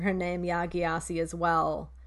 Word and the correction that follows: Gyasi: So can you say it one more time? – Yeah, Jossie. – Ya Jossi. Okay. – Like the way I wrote it down, so I would pronounce it correctly, was YAJACI. Yeah, Jossie. But that, I mean, I'm just saying for phonetics, that's not, it's spelled GYASI, you Okay.